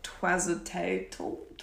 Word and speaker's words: Tlazolteotl: Okay, so it's spelled T-L-A-Z-O-L-T-E-O-T-L. And it's kind of pronounced like Twazatatl.